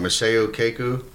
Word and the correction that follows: Michio Kaku: Michio Kaku.